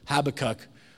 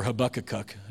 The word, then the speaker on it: Habakkuk: Habakkuk or Habakkuk depending on your pronunciation Habakkuk 2 tells us uh, the, well, no, I'm sorry.